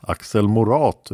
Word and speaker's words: Axel Morat: Men för oss som var med på den tiden och hörde namnet ska den sista stavelsen uttalas så att det rimmar med lat. Axel Morat utan H med betoning på A.